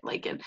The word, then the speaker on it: lichen: So it's actually a lichen, right? That's how you say it, lichen.